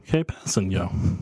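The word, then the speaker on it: Kpassagnon: No, it's K-P-A-S-S-A-G-N-O-N, I believe. – Capassigno. Kapasanon? – Tano Kapasanyo. That's how the pronunciation goes.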